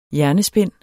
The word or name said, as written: HJERNESPINDENE